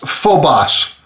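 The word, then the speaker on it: phobos (φόβος): phobos.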